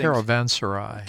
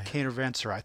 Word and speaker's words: Caravanserai: And based on his bio, most notably played with Santana on Caravania. Is that how? I don't know. We butchered things. – Caravanserai. – Caravanserai. Thank you, sir.